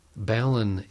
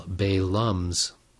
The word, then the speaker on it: balun: For the record, balun is a contraction of balanced and unbalanced, and is pronounced accordingly. Baluns, I guess, can only be properly used by hams who live near the water, San Francisco or Tampa perhaps, while balums should probably never be used or spoken of aloud.